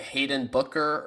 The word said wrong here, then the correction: Hendon Hooker: Hendon Hooker.